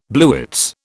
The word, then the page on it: blewits: British English